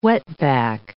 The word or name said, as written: wetback